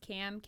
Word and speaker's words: Kam: You can either find me on Nick's page or it's just Cam, K-A-M. Renee, R-E-N-E-E, Fit.